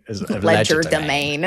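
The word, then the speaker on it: legerdemain: And we pronounce it in this sort of obnoxiously English way. – Ledger domain.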